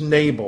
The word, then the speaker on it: Nabal: The Hebrew word is Nabal, or you might pronounce it Nabal, N-A-B-A-L.